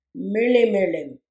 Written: mirlimirlim

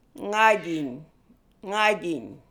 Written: Ngajiny

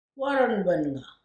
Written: Warrernbennga

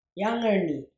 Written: Yangirni

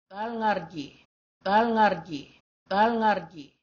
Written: thalngarrji

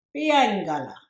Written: Biyanygala

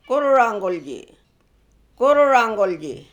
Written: Goorreranggoolji

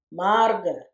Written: marrge

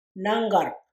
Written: nanggarrg

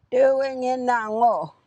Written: Thiwingenhangoo

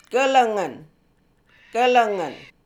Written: Gelengen

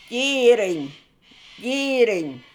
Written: Jiyirriny

